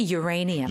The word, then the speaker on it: uranium: 그리고 사실 우리는 우라늄이라고 발음을 하거든요. – Yes. – 근데 쌤은 지금 조금 다르게 하신 것 같아요. – We say uranium.